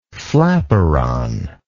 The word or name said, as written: flaperon